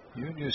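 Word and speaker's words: Yunior: Junior Severino, first name is like Junior, but it is spelled with a Y and pronounced that way.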